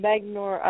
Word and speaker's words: Magno: We are here with Ben Magno from Magnor.